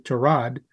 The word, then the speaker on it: Tyrod: I guess it's Tyrod. Let's get it right, because I know that he does not correct people. But he said his mom gave him that name and she always pronounced it Tyrod, although she never called him by that name because she called him by his middle name, which is Diallo.